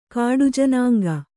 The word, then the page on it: ಕಾಡುಜನಾಂಗ: kāḍu janāŋga